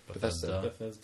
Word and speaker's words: Bethesda: Bethesda. A lot of the games. Is that how you say it? – Bethesda.